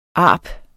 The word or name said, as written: arp